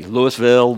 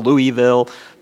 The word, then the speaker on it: Louisville: Did I say it right? Louisville? Yeah, you can always tell when someone hadn't been in Kentucky. Louisville, Louisville, Louisville.